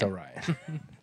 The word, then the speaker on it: Orion: Orion? – Orion. – It's Orion. That is a fact.